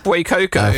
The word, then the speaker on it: Fuecoco: Is it Fuecoco? Is that how we're pronouncing that? – Fuecoco.